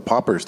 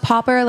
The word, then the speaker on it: pauper: Oh, Popper, like P-A-U-P-E-R. – Did I spell it wrong? I guessed it. – You didn't even spell it on the thing. – I think I tried to spell it out. – Popper, like poor. – Yeah, like poor.